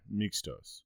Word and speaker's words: mixtos: So there's a term called mixato. I think is how it's pronounced. Mixtos.